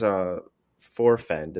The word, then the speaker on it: Forefend: In fact, even his Forefend, is that how you say it? – Yeah.